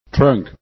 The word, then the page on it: trunk: english